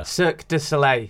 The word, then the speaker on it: Cirque du Soleil: I can't pronounce it. – Cirque. – Cirque. – De. – de Soleil. – Cirque du Soleil. – Now say it all together. – Cirque du Soleil. Good.